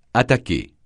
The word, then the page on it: attaquer: francais